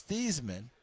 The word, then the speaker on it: Theismann: Because it was Theismann. And then his SID at Notre Dame, Roger Valdeseri, the great sports information director, said, we're going to change it to Theismann because it rhymes with Heisman.